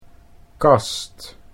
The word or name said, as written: kast